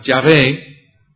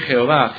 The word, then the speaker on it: Yahvé: Me refiero más bien a la pronunciación y a las vocales. Creo que Yahvé refleja cómo sería este nombre personal de Dios en hebreo originalmente, Yahvé. Entonces, creo que esta forma es mejor que Jehová. Jehová, en mi opinión, yo puedo estar equivocado, pero Jehová, en mi opinión, probablemente no refleja de forma correcta la pronunciación de este nombre de Dios.